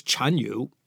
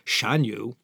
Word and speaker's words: Chanyu: the Xiongnu leader's title was Chanyu. You'll see it also spelled and pronounced as Shanyu. So, Maudu Chanyu was a pretty major influence on all the events that started to happen in East Central Asia, including in Xinjiang.